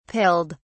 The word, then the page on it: pilled: British English